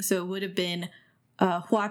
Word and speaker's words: Hua: Funny enough, mentioning a flower, the fact that Hua is the correct pronunciation of flower in Mandarin.